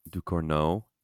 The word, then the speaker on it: Ducournau: It's directed by, I'm going to butcher her last name, Julia Ducournau. – Ducournau.